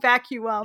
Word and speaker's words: vacuum: you have to say it like vacuum yeah. to rhyme with rum um because if you don't it doesn't work but it's so clever and it's just I wish my brain worked like that.